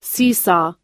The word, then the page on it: seesaw: American English